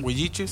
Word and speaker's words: Huiliche: Huiliche, ¿está bien? – Huiliche, sí. – Huiliche, es que me cuesta pronunciar la doble L.